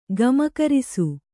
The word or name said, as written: ಗಮಕರಿಸು